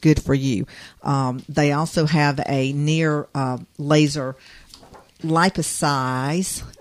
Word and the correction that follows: lipolysis: That's liposize? Right. It's basically laser lipolysis.